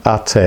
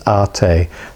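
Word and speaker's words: Ateh: Yeah, so the first one is called Ate, it's A-T-E-H. At Ate. So if you're doing it, phonetically, it would be A-R-T-A-Y. I'll do it phonetically. That's A-R-T-A-Y. Ate. It just in Hebrew, it means thou.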